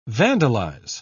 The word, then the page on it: vandalise: British English